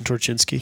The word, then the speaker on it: Torchinsky: So as part of this Jalopnik article, Torchinsky, I think is how you say his name. – Yeah, Jason Torchinsky.